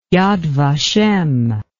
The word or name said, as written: Yad Vashem